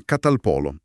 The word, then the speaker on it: catalpolo: Si chiama catalpolo, occhio alla pronuncia. Catalpolo, con l'accento sulla seconda O.